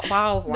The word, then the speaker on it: balsam: But you said Boslam. Because that's how it's spelled. – Dyslexic. – Balsam. – No, it's balsam. – Balsam. – Okay, you mixed up the L and the S and said Boslam.